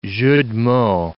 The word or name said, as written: jeu de mots